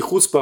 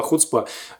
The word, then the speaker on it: хуцпа: Написали мне: мы живем в Израиле, вы неправильно слово произносите. Не хуцпа, а хуцпа.